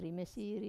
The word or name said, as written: rime siri